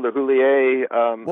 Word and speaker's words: LeHoullier: And you do pronounce it LeHoullier, right? – LeHoullier, LeHoullier. I have traced it back to Normandy, France, mid-1700s. But if you just pronounce what you see, it gets you close enough.